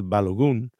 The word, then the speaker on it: Balogun: And our strikers are Balogun, or as I found out, he's actually pronounced Balogun. Balogun is a much better.